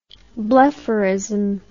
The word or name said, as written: blepharism